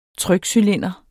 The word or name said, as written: trykcylinder